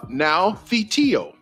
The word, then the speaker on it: noutheteo: Again, that word admonish, A-D-M-O-N-I-S-H, is the Greek word for now theteo. Ooh, that was pretty good, y'all. Now theteo. That's spelled N-O-U-T-H-E-T-E-O.